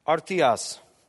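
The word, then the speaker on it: artios: That Greek word there is pronounced artias, for complete or perfect.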